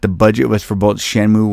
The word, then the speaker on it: Shenmue: Shenmue.